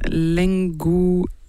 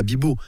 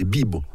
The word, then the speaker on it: Lengobibo: Il ne faut pas mettre l'accent tonique sur la dernière. Ce n'est pas bibo, c'est bibo. Si on met l'accent tonique, c'est sur le i.